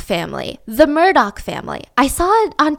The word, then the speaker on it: Murdoch: The running dark joke was that this is the, it's spelt like Murdo, right? That's kind of how you want to pronounce it. So everyone's like, oh my God, the murder family. But it's pronounced the Murdoch family.